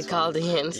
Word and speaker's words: Chaldeans: And he said unto him, I am the Lord that brought thee out of Ur of the Chaldeans. How do you pronounce it? – The Chaldeans.